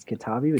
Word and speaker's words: Kitabi: So it's, I know it's a K-I, and like phonetically for us as Americans, it's Kitabi.